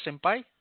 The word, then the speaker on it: Senpai: Senpai. Did I pronounce that right? Senpai? – Yes, Senpai.